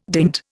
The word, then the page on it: dint: Indian